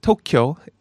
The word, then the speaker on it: Tokyo: So it's not Tokyo, it is Tokyo.